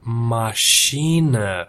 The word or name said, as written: masina